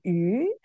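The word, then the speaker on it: yu (鱼): Because in Chinese, it's called yu, and it's the same pronunciation as like abundance.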